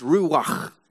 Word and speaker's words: ruach: And the word for spirit was, let me say this right, ruach. Ruach. You have to go. I mean, if we pronounce it our way, it would be ruach. But it's ruach. And that's how you pronounce it in original Hebrew.